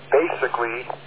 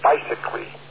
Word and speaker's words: basically: By the way, when it comes to pronunciations, you very frequently will pronounce the word basically as bicycly. You might want to pronounce it basically, not bicycly. Some people will have trouble understanding what you mean by bicycly.